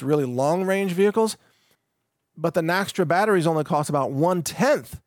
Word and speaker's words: Naxtra: And Naxtra is spelled N-A-X-T-R-A, Naxtra.